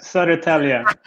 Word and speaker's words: Södertälje: Södertälje? – Yeah, it's not too bad. Södertälje.